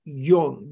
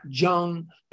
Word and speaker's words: Jung: J-U-N-G is pronounced Jung, not Jung, not Jung, but Jung."